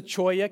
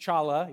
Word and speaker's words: cholla: This is a cholla cactus. It's not pronounced chala, even though my wife and I were like, chala, chala. It's actually a cholla. That's how you say it.